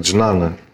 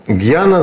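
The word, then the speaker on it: гьяна (ज्ञान): Слово гьяна в переводе с санскрита означает знание. Иногда произносится как джнана, слово.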